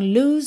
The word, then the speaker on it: lose: Na segunda linha, temos lose, o E final pintadinho de cinza não vai ser pronunciado, você já vai juntar o S do lose no so. Então, lose so many tears.